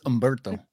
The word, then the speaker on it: Humberto: All right, Spider-Man number 7, the Humberto Ramos top secret spoiler variant. I kind of rolled my R there. Did you hear it? – Silent H, too. It's Humberto.